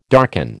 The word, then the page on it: darken: American English